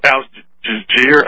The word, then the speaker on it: Al Jazeera: Yeah, they're saying that the CIA probably is in charge of Al Jazeera, I can't pronounce it.